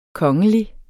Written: kongelig

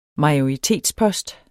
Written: majoritetspost

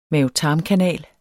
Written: mave-tarm-kanal

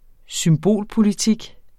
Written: symbolpolitik

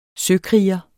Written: søkriger

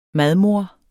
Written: MADMODEREN

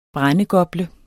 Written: brændegople

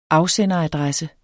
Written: afsenderadresse

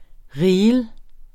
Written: reel

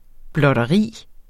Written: blotteri

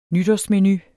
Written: nytårsmenu

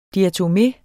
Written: diatomé

